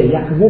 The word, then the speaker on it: یقظه: هر بخشی هم از خدم 10 تا منزل داره که جناب حاج عبدالله انصاری از یقظه یا یقظه، یقظه تلفظ درست داریه، یقظه شروع میکنه.